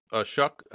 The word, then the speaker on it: Shough: Next year, made the announcement, Tyler Shough. Shuck, I believe it's pronounced, is coming back for his 7th season at Texas Tech.